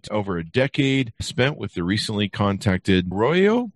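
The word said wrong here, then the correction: Ayoreo: Welcome to the show, Lucas, and did I butcher the Arroyo people? – Thank you so much for having me. Yes, you butchered that. – I'm sorry. – But it's all right. – How do you pronounce it? – Iareo.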